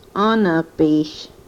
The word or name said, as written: onopish